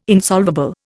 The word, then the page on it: insolvable: Indian